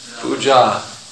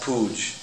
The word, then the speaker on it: puja: Puja, not Vyas Pooj. We hear there's some nasty meaning to it. Puja, you can't drop a long A. They're not droppable.